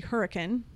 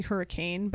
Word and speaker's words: Hurricane: Another place that I like to go instead of Moab is actually Hurricane. And it's actually Hurricane, but we pronounce it Hurricane. So if you call it Hurricane, you're going to out yourself as a tourist.